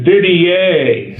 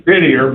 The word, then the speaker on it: Didier: But they go, Clint Didier, and his name is Didier, but, you know, I guess they assume it's the French pronunciation.